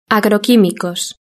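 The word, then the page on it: agroquimicos: Spain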